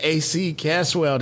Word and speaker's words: AC Caswell: And speaking of AC, I'm with my man, AC Caswell.